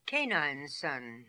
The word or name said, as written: Canineson